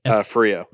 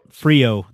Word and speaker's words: Frio: How do you say that river that he's on? Is Frio. – Frio, the Frio River.